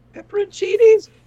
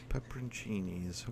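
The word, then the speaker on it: Pepperoncini: How do you pronounce those? – Pepperoncinis. – Pepperoncinis, okay. – The N in there always throws me off. I'm not sure if I'm supposed to say the N or not.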